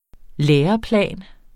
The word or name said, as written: læreplan